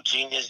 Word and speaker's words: Jeanius: You know, just Google Genius, JEANIUS. So I spell it differently.